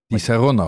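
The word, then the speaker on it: Disaronno: En hij was finalist van Absolute Invite, World Class, Bacardi Legacy en Di Sanoro. Maar dat moet je dan op zijn Italiaans uitspreken. – Di Sanoro.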